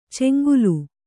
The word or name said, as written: ಚೆಂಗೂಲಿ